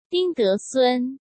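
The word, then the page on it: Ding Desun: Chinese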